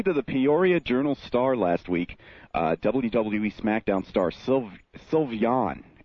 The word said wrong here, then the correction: Sylvain: Am I pronouncing that right? – Sylvain. Sly.